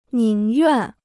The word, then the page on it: 宁愿: nìng yuàn